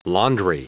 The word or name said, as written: laundry